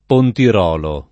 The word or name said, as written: Pontirolo